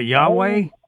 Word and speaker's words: Yahweh: You're talking about Yahweh? – That's what I mean. That's the way we say it is Gentiles or whatever, but it's spelled YHWH, no vowels.